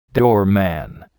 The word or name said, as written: doorman